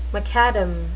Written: macadam

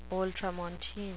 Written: ultramontane

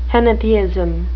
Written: henotheism

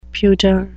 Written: pudeur

